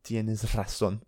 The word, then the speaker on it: razón: Tienes razón. I always find that difficult to pronounce. – Razón. – Tienes razón. It's the S sound followed by the R.